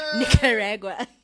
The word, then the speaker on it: Nicaragua: Nicaragua.